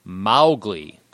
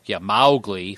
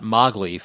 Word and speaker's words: Mowgli: So, and according to Elsie Kipling Bainbridge, Rudyard Kipling's daughter, Mowgli is pronounced Mowgli. Mowgli, first syllable rhymes with cow, not Mowgli, first syllable rhymes with go.